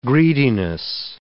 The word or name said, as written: greediness